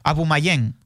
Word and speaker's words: Aubameyang: ¿El Aubameyang o cómo? – Aubameyang. – Aubameyang.